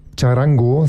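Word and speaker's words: Txarango: Charango, sage ich jetzt einfach mal, schreibt sich auch mit TX am Anfang, spricht sich wie Charango, dieses kleine Instrument, ich sage nur Ukulele, hat man ja auch als Spezialinstrument hier eine Themensendung, ist so ein ähnliches Instrument.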